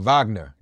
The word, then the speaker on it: Wagner: And we're saying it wrong. Wagner.